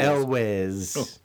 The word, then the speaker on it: Elwes: It must be with Carrie Yules. – Yules. – Elwes.